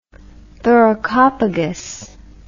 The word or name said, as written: thoracopagus